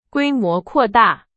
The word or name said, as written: 规模